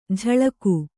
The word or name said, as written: ಜಳಕು